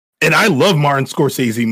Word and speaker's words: Scorsese: And, you know, what is it, Scorsese? I don't know if I'm saying his name right. – Yeah, Martin Scorsese.